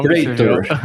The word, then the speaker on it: Praetor: O Phenom 100, Phenom 300 e os Pretors, que eu não sei nem se é assim que se fala a pronúncia.